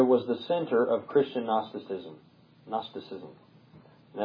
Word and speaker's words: Gnosticism: So they combining this philosophy with the teachings of the New Testament produced the heresy of Gnosticism.